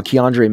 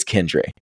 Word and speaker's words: Kendre: Yeah, I'm just proud that you said his name right. We've corrected you so many times, Davis and I. We'll be prepping for the show, and Josh is like, oh yeah, I think we should talk about Keandre Miller. I'm like, Josh, it's Kendre.